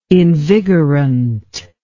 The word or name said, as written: invigorant